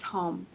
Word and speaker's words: Home: My last name is Home.